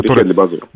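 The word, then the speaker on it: Riechedly Bazoer: Это не было сливом, потому что у него был открытый конфликт, поправь меня по ударениям, Рихедли Базуром или Базуром, да? – Рихедли Базуром.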